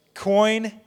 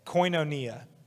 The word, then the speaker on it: koinonia: Koinonia. That's how you say it. Koinonia.